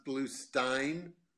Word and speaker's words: Bluestein: My last name's pronounced Bluestein, like beer stein.